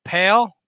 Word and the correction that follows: Pele: Pale? Pro uh, wrestling? – Pele. Pele Pro. Pele. Yeah. Yeah.